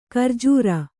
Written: ಘೂರ್ಜರಿ

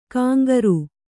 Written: ಕಂಕರೆ

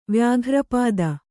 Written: ವ್ಯಾಘ್ರಪಾದ

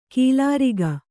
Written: ಖಲೂರಿಕೆ

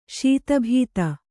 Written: ಶತಾಬ್ದಿ

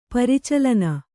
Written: ಪ್ರಚಲನ

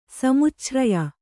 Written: ಸಮುಚ್ಛ್ರಯ

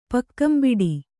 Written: ಪಕ್ಕಂಬಿಡಿ

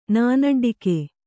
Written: ನಾನಡ್ಡಿಕೆ

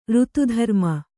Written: ರಥದ್ರುಮ

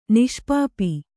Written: ನಿಷ್ಪಾಪಿ

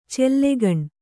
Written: ಚಲ್ಲಗಣ್

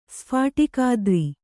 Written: ಸ್ಫಾಟಿಕಾದ್ರಿ

